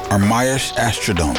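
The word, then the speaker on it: Ermias Asghedom: Ermias Ashkodome.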